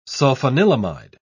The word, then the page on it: sulfanilamide: British English